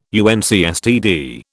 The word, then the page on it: UNCSTD: British English